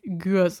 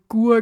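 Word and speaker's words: Gurs: Also es heißt Gürs und nicht, ich glaube, ich habe Gur gesagt oder so.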